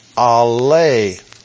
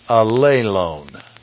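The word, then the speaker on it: allelon: And it is the word A-L-L, alelone, long O, alelone.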